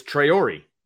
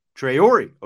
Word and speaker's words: Traore: It's Traore. – Traore, okay. – Yeah. – So it's not a silent E. Okay. – I need to let some of the people on the beat know that because they're butchering it. But it's Traore.